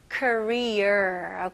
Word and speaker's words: career: 자, 한국은 Korea 이렇게 끝부분이 중요합니다. 시작은 사실 거의 똑같아요. Korea 그리고 이거는 career. Okay. 이 r로 끝나는 단어들은 힘을 주시면서 r를 명확하게 발음해 주셔야 됩니다. Okay? 자, 해 볼게요. career. career. 이거는 좀 끝부분에 이렇게 약간 게을르게 그냥 career 이렇게 하면 한국 같이 들려져요. 그래서 career. career. 혀가 당기는 느낌이 나야 됩니다.